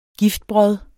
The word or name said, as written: giftbrod